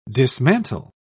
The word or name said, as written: dismantle